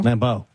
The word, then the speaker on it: Lambeau: Number 3 is Lambeau. Mm. How do you say it? – Lambeau.